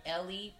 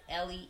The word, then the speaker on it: Elli: But on Facebook I was Elizabeth Elli, but it's spelled like Eli Walton. But right now I'm on IG as Elli underscore De Fatima DEFATIMA.